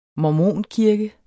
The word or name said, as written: mormonkirke